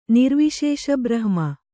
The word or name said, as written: ನಿರ್ವಿಶೇಷಬ್ರಹ್ಮ